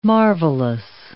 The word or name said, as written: marvelous